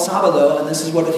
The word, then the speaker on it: Sábado: Sábado.